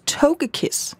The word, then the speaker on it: Togekiss: Jeg har fået af min kæreste, jeg tror det udtales Togekiss. – Ja. Det er meget rigtigt.